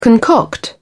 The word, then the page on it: concoct: British English